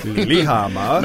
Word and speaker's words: Lillehammer: But you have to say that with an accent, too. – Lillehammer.